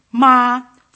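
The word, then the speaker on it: Ma (妈): The first tone is high. Ma, first tone, means mother. Ma, ma, the second tone has a rising inflation.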